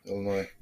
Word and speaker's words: Illinois: Illinois. So the S is silent. It's like knife. Knife. – Yeah. – There's no K there. It's knife. Okay.